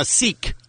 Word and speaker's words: Sikh: She became a Sikh.